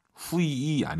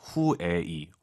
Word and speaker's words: 会: 후이 아니죠. UI 사이에 에 넣으셔야죠. 후에이. 워낙 빠르게 발음하니까 잘 안 들릴 뿐이죠.